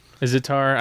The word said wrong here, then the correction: sitar: Are you talking about a sitar? – A sitar. Thank you.